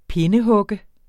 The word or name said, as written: pindehugge